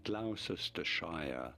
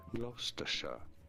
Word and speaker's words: Gloucestershire: No, it is not Gloucestershire. It may look like it, but it is to be said as Gloucestershire.